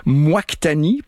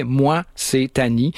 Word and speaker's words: Moictani: Moi, c'est Annie que j'ai prononcé pendant trop longtemps Moictani, parce que c'est comme ça que ça s'écrit, Moictani. C'est le pseudonyme d'une artiste hispano-suisse qui s'appelle Tania Praz. Donc, c'est littéralement « Hey, moi, c'est Tani ».